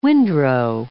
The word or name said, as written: windrow